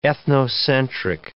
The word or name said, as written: ethnocentric